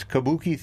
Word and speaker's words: kabuki: It's kabuki theater.